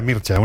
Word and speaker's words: Mircea: Rumana, él se llama Mircea. Mircea. Sí, se pronuncia Mircea, uno que es muy friki de todos los rumanos, ya lo sabéis.